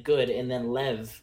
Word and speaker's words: Lev: Lev.